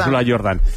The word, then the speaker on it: Úrsula Jordán: Que se escribe Jordán, pero como Michael Jordan, ¿no? – Como Úrsula Jordán. – Úrsula Jordán.